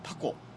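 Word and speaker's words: 多幸: 八本足でですね、多い幸せと書いてタコ、タコと読む縁起の良い生き物とされているのでですね、まあ、 7月 2 日半夏生の日にはですね、タコを食べるのもいいかなと。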